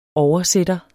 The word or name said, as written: oversætter